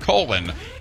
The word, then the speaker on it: Colon: They literally pronounce it Colon, and it's the magic capital of the world.